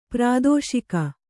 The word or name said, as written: ಪ್ರಾದೋಷಿಕ